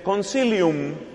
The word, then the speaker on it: Concilium: Otro documento se llama Sacrosanctum, Sacrosanctum, Concilium se pronuncia y se escribe Concilium, acabando en la letra M de mamá.